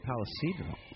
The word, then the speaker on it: Palo Cedro: And I got to pronounce the name Palo Cedro. – Oh, pardon me. Palo Cedro.